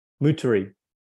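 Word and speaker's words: Moutere: Is it, do you pronounce it Mutri or Muteri? – Muteri. Muteri. Upper Muteri.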